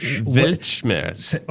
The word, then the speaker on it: Weltschmerz: Weltschmerz.